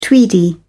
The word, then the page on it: tweedy: British English